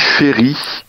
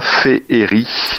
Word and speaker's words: féerie: On dit féri et non pas féeri. Alors, un petit bémol quand même, parce que la réforme orthographique de 1990 a officialisé la prononciation de féeri et féerique pour se conformer euh, à la prononciation la plus courante.